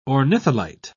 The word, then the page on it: ornitholite: British English